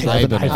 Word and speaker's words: Ibanez: How do you pronounce that? Ibanez? – Ibanez. – Ibanez. – Short vowel. Ibanez.